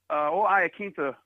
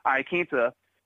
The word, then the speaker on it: Iaquinta: Al Iaquinta versus Paul Felder, because Iaquinta over here. Excuse me, I was mispronouncing his name. Iaquinta.